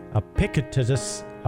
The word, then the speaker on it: Epictetus: There was one of the ancient guys, and I'm hoping I'm pronouncing it right, Epictetus is how you pronounce it.